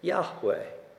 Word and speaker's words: Yahweh: In Hebrew, YHWH. We add the vowels in and we pronounce it Yahweh.